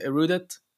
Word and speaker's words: Erudit: Erudit AI. Am I pronouncing that correctly, by the way? Is it Erudit? – Yeah, Erudit, whatever you want.